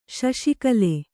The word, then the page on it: ಶೌಷ್ಕಲ: śauṣkala